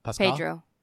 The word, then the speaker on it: Pedro: Paulo Pascal, that's how you pronounce his name, right? Or Pascal? – Pedro Pascal.